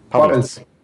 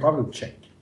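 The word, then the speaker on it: Pavelec: Men det är Ondrej Pavlets. – Pavlets. – Pavlets. – Mm. Pavlets. Jag säger alltid Pavletschek, men det gör man inte.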